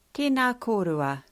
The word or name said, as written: tena korua